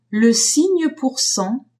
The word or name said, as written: signe pour cent